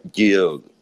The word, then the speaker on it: yield: Me mandan un WhatsApp y dice, se pronuncia yield.